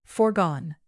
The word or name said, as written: foregone